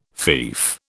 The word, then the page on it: fief: British English